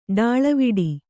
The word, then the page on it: ಡಾಳವಿಡಿ: kannada